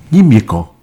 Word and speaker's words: Nimiekan: Est-ce que je prononce bien Nimiekan? – Oui.